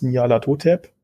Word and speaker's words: Nyarlathotep: Ähm, ich glaube, das waren die Masken des Nyarlathotep.